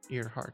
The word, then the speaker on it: Earhart: Earhart. – Earhart. Earhart. It's spelled E-A-R. – Like an ear and a heart.